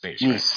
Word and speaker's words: Nice: That Frenchy Nietzsche, or is it Nice? I think it's Nice.